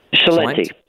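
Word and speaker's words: Celente: Is it Salente or Salenti? – Salenti.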